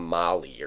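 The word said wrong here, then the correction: Mahle: I think it's pronounced Maley yeah. actually.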